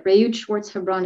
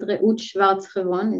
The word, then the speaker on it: Reut Schwartz-Hebron: And I don't remember what my URL is, but Reut Schwartz-Hebron is not a very common name to find, or as you beautifully pronounce it, Reut Schwartz-Hebron is not common.